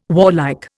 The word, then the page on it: warlike: Indian